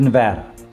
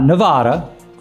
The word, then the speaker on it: Nevada: And today we're going to go out west to the great state of Nevada. Not Nevada, first lesson, it's Nevada. Some candidates for president have run aground on that pronunciation.